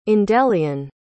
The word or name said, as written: endellion